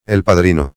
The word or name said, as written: el padrino